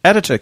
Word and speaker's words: Atatürk: Turkey's founder, Mustafa Kemal Atatürk, sorry, I really can't pronounce that. That's Atatürk, is it? – Atatürk, that's the one.